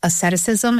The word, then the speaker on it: asceticism: Like the, you said asceticism. Is that how you say it? I forgot how to say it. Yeah. As a bilingual, I get my wise. – Yeah, I love it. No, you did it.